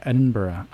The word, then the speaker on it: Edinburgh: It's it it is like it's finished with an A. Yeah, Edinburgh.